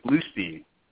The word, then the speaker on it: Blustein: It's actually Bluestein.